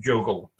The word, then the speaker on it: JOGL: And so all of these organizations, there's also a European consortium called Just One Giant Lab, or it's pronounced JOGL sometimes, are, with some minor confusion, dedicated to making free Libre open source solutions.